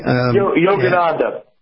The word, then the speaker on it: Yogananda: And the person that seems to be consistent and complicit, well, several people, was Anaconda. I may be saying that wrong. – Yogananda. – Yogananda, yes. Yogananda, who T.K.